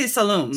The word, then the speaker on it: Saloum: Saloum? Is that how it's pronounced? – I would say Saloum. – Saloum.